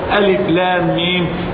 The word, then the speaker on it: الم: ونبينا ما تعلم، نبينا ما كان يقرأ ولا يكتب، بل كان أميا، لكنه كان يذكر ألف لام ميم كما كتبت في ألم نشرح لك صدرك، فنطقها ألم هنا ونطقها ألف لام هنا،